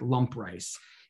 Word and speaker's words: lamprais: It's spelled L-A-M-P-R-A-I-S, but it's pronounced like lump rice.